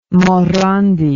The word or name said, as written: Morandi